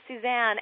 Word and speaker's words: Suzanne: Exactly, because I can say, hi, I'm Suzanne, and 1 second later they'll say, hi, Susan. And I'm thinking, no one said that. That's spelled and pronounced differently.